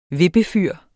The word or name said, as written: vippefyr